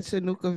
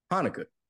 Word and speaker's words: Hanukkah: Oh my goodness, that Chinooka video was just so much. – For those that don't know, if you want to laugh for a good 15 minutes, listen to Smokey Robinson try to pronunciate Hanukkah.